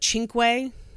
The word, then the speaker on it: Cinque: And then we have the New York Times article, Cinque, which, by the way, it's spelled like C-I-N-Q-U-E, where you would think it would be like Cinque, but it's Cinque, and that's the name that DeFries adopts for himself later on.